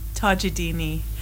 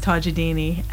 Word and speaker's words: Tajoldini: So, Tajoldini. – Tajoldini. – Even though it's spelled like... I'll tell you how it's spelled. It's Tajoldini, but it's pronounced Tajoldini, and not a lot of people can say it, so Tajoldini.